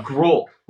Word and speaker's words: Gruel: Gruel, like the porridge.